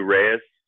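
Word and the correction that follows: Rhys: I believe it's Reese, Matthew Reese.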